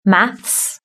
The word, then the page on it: maths: British English